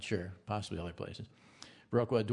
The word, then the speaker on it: Viroqua: It's Viroqua, isn't it? I always say it wrong. – Yeah, Viroqua.